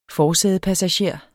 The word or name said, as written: forsædepassager